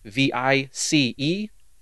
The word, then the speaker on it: Plitvice: How do you say that? What lakes? – Plitvice.